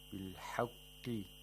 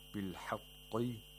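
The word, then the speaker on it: بِالْحَقِّ: Anzal al-kitaba bil-haqqi wal-mizan bil-haqqi. The qaf has to be pronounced with the lowest level of tafkhin. If you say what bil-haqqi, that's too much tafkhin.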